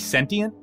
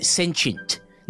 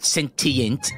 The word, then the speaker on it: sentient: It's pronounced sentient. Not sentient, it's soft, sentient.